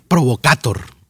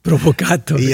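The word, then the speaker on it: Provocator (провокатор): ¿Sabes cómo se dice provocador en ruso? ¿Cómo? Provocator. Provocator.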